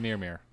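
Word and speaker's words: Mjölnir: It's pronounced Mjolnir.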